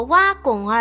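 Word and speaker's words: 凹凸: 只是这两个字俗念作挖拱二音，便说俗了，不大见用。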